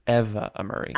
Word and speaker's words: Amuri: Also, I hate to do this to you, but you pronounced her name wrong again. It's Eva Amuri.